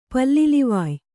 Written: ಪಲ್ಲಿಲಿವಾಯ

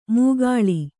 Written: ಮೂಗಾಳಿ

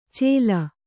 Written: ಚೇಲ

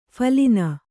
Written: ಫಲಿನಿ

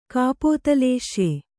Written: ಕಪೋತಲೇಶ್ಯೆ

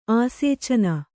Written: ಆಸೇಚನ

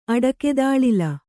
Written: ಅಡಕೆತಾಳಿಲ

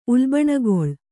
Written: ಉಲ್ಬಣಗೊಳ್

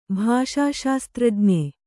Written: ಭಾಷಾಶಾಸ್ತ್ರಜ್ಞ

